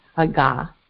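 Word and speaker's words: haga (הגה): And the Hebrew word for to meditate is spelled H-A-G-A-H, haga. And you kind of have to do this kind of growl thing going on in your throat, haga, like that.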